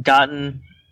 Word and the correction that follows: Gaten: Matarazzo. I'm butchering his name. – Gaten, I think, is his name.